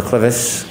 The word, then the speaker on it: clivus: Were you a presenter in fractures of the clivus, clinical and radiographic features? – Yes.